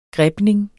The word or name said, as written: grebning